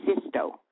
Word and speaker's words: Sixto: Now, can you tell me how you landed that role as Sister Sixto, and it's spelled S-I-X-T-O, but it's pronounced Sisto. S-I-S-T-O.